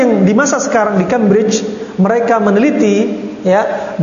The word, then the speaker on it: Cambridge: Dan mereka di Cambridge, ya, Bacanya betul ya, Cambridge. Iya.